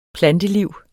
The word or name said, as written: planteliv